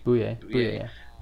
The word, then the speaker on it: Bouye: I don't know if I'm pronouncing the name right. AJ Bouye. – Bouye. Yeah.